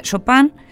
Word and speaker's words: Chopin: Se pronuncia Chopin en francés porque tenía, si bien ella era norteamericana, tenía familia francesa y su apellido venía de Francia.